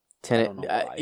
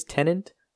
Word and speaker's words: Tenet: I always feel like I'm saying it wrong. – Tenet. You know what? Honestly, for the longest time, I thought it was tenant, like the tenant of an apartment complex or the tenant of a.